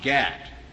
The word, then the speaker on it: GATT: And indeed, in the General Agreement on Tariffs and Trade, an acronym pronounced GATT,